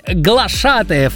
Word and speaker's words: Глашатаев: Во всех формах данного слова ударение падает только на второй слог. Вот это надо точно знать, поэтому правильно говорить «Глашатаев».